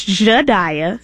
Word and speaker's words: Jhadiya: My name is Jedidiah Steele, which is J-H-A-D-I-Y-A, Steele.